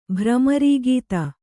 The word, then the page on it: ಭ್ರಮರೀಗೀತ: Kannada